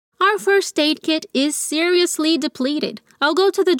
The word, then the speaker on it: seriously: Our first aid kit is Atenção para essa pronúncia, vamos dividir a palavra. Se, seriously, depleted.